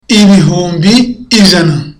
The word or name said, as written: ibihumbi ijana